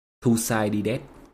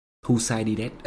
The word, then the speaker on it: Thucydides: Ví dụ Thucydides đọc là Thucydides. Thucydides. Ok.